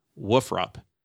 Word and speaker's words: WFRP: It says, Warps is proceeding to plan, although the name has been changed to, and it lists WFRP, and then in parentheses it says, pronounced Woofrup, Warhammer Fantasy Roleplaying.